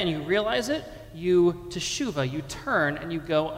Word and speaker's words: teshuva: Let me hear you all say teshuva.